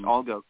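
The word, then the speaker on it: Allgäu: Games of the season, the Erfurt Indigos, pardon my pronunciation while I butcher this, was it Algo Comets? – Yes, Algo Comets.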